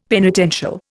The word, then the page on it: penitential: Indian